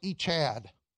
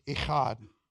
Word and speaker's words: Echad: E-C-H-A-D. It's like Echad, only you can't say it like that. It's Echad, okay? Long ah, aw, like that, like father. I am your father, that kind of thing, okay? So Echad, okay?